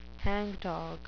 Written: hangdog

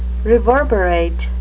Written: reverberate

